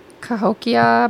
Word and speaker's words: Cahokia: How do you say that? – Cahokia Mounds Way of Springfield. – You know what? I think that's exactly how I would have said it. – Yeah. Okay. – Sounds legit. – C-A-H-O-K-I-A.